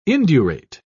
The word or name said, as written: indurate